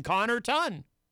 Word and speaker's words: Connarton: I mean, the first two syllables are Connor. So just add the ton. Connarton.